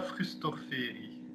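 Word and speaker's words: fruhstorferi: Ah, de Furs Forti, zeg ik dat correct? – Titanodula Frustorferi. – Ah ja, Frustorferi. Excuseer.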